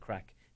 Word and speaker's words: craic: So when we do the storytelling stuff, it's actually, as we say in Ireland, great crack. Now, you can't say in Tennessee it's great crack, right? It's just not, not the, but we pronounce it CRAIC, great crack, and which means really great fun.